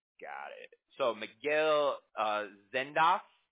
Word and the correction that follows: Zendejas: did I say it right? – Zendejas, yeah.